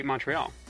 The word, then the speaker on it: Montreal: From Monaco, as I said, intending to say Montreal. – No, that one's pronounced Montreal. – Ah. – Yeah. That one's actually got a Midwestern drawl to it, ironically enough.